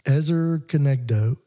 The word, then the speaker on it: Ezer Kenegdo: But it's Ezer, E-Z-E-R, and it's Kenegdo, K-E-N-E-G-D-O. – Got it. – Ezer Kenegdo.